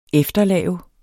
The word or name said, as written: efterlave